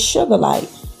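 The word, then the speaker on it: Sugilite: As you go through this portal of freedom, is sugar light. And I'll spell it for you. It's S-U-G-I-L-I-T-E. Sugar light.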